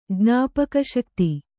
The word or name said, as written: ಜ್ಞಾಪಕಶಕ್ತಿ